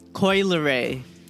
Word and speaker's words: Coi Leray: Coi Leray.